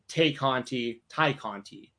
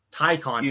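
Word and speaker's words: Tay Conti: Ty Conti. – Either one, right. I think, will work. – Yeah. It's spelled Tay, though, and that's why it always throws me off.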